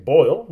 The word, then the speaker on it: Boyle: Looking at Nick Boyle, rhymes with Jack Doyle, may not play, so that should help Mark Andrews against the Steelers.